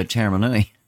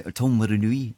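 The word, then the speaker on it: Taumarunui: For Waikato to Tamaranui, how do you say that? We always used to go Taramanui. Everyone says Taramanui. People that live there, but all the kids say Tamaranui, don't they? All to say it properly.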